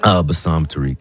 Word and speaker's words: Bassam Tariq: Bassam Tariq. – Bassam Tariq.